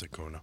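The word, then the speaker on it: Acuña: Is that how you say his name? Ronald Acuna? – Yeah, it's Acuna.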